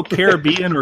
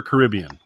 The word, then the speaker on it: Caribbean: Do you go Caribbean or Caribbean? Which is your preferred pronunciation? – So it's weird because I've heard it both ways. I've seen people associate it with the movie, call it Pirates of the Caribbean. So I go with that because I feel like that's the artist's intended pronunciation, if you want to do it that way.